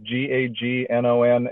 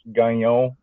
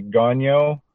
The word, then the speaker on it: Gagnon: So the last name is Gagnon, and it's pronounced Gagnon if you're French. – Gagnon. – But to everyone else, you can say Gagnon.